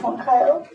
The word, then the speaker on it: Moncayo: My name is Candice Moncayo. Last name is spelled M-O-N-C-A-Y-O.